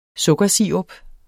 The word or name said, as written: sukkersirup